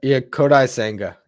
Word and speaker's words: Kodai Senga: Is it Kodai Senga? How do you pronounce the dude's name? – Yeah, Kodai Senga.